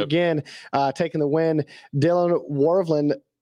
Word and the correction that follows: Walraven: Is that how you pronounce the name? – Walraven.